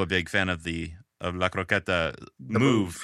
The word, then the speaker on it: croqueta: Yeah, La Croqueta. Very good. Very good pronunciation.